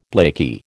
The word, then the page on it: blakey: American English